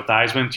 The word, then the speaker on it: Theismann: He's become known nationally for changing the pronunciation of Joe Theismann to Joe Theismann to rhyme with Heisman.